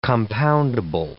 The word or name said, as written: compoundable